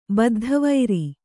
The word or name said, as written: ಬದ್ಧವೈರಿ